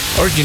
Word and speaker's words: Orgy: ¿Cómo se pronuncia? Es como orgía en inglés. – Orgy, ¿no? – Sí.